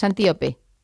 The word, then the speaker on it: Antíope: ahondas mucho en la relación de Teseo con Antílope, pero me gustaría no, lo he dicho mal el nombre, es Antíope.